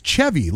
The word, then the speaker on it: Chevy: So if you're saying it Chevy like a vehicle, you're a bumpkin. – Whoa. – Well, you're a bumpkin. That's not how you say his name. It's Chevy like the chair, like I've been saying.